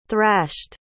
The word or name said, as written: thrashed